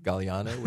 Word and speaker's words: Galliano: It's hilarious because they tend to get my name correctly, Galliano, which has a silent G in it.